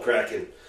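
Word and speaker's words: KRAK'IN: And it's spelled K-R-A-K. apostrophe I-N. – Nice. – It's called Kraken.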